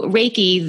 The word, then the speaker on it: Reiki: So Reiki, the word itself is spelt R-E-I-K-I. And I break it down into two words, Rei like the sun and Ki, which also is the energy force.